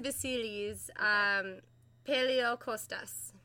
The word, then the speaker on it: Vasilis Paleokostas: It's pronounced Vasilis Paleokostas.